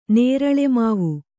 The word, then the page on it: ನೇರಳೆಮಾವು: Kannada